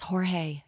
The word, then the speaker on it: Jorge: George, you're there on bass. Yeah. And some people are saying, you're saying his name wrong. It's Jorge. Oh.